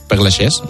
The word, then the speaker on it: Père Lachaise: No hay autopsia, fueron 5 personas solo al entierro en el cementerio de Père Lachaise, creo que se pronuncia. Père Lachaise, merci.